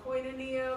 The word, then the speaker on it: koinonia: See, now she knew how to pronounce the word. – Yes. Say that again, please. – Koinonia. – There you go.